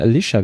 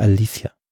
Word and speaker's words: Alicia: Übrigens, Alicia Silverstone, du hast vorhin Alicia gesagt. – Ach so. – Hat sie sich vor ein paar Jahren mal drüber beschwert, dass sie seit Jahrzehnten falsch ausgesprochen wird. Also sie heißt tatsächlich Alicia. So spricht sie.